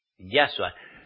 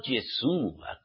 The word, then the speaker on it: Yahshua: Yahshua, no Yeshua, como muchos lo pronunciáis.